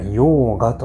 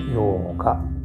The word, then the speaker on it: ヨーガ: で、ね、結構このヨガをあ正確にはヨーガというみたいですね。 – ヨーガ。ヨーガというふうに発音するみたいです。そう。で、このヨーガですね、えー、実は、質の良い瞑想をするための前の段階でヨーガというのは目的ではないんですよね、これを。